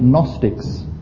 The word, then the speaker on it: Gnostics: And the two extremes are well illustrated in the New Testament because of people called Gnostics. Gnostic is spelled G-N-O-S-T-I-C.